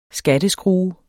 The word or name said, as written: skatteskrue